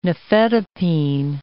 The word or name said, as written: nifedipine